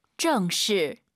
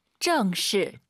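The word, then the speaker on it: 正式: 사성을 발음하실 때 이걸 이제 아아 정식 하도 괜찮지만 사람이 대화하는 거잖아요. 힘을 빼고 한번 해보시죠. – 정시. – 정시. 이런 식으로 말이죠.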